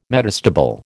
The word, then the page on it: metastable: American English